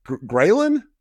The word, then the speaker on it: ghrelin: So I thought when I was reading through everything too, I saw that there was something with an appetite hormone called, ghrelin? Did I say that right? – Correct.